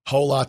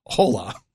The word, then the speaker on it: hola: Hola. – Are you kidding? – He's trying. – The H is silent. Try it again. – Hola. – The H is silent.